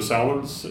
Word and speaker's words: Salmons: My name is Joe Solomons, and that's spelled S-A-L-M-O-N-S.